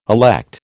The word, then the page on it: elect: American English